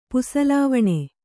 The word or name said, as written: ಪುಸಲಾವಣೆ